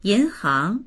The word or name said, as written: yin2hang2